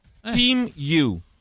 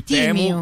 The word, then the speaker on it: Temu: Si pronuncia Team You. – Team You. – Con la I. Team You. Quindi non dite più Temu, che fate per ride se dite Temu.